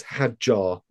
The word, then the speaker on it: Hadjar: It's Hadjar, like Jar, not like...